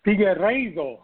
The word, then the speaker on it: Figueiredo: Figueiredo, yes, sir.